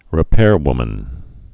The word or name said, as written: repairwoman